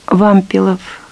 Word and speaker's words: Вампилов: Хозяин Вампилов Валентин Никитич. Буряты просят эту фамилию произносить с ударением на первый слог.